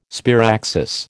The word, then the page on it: sparaxis: American English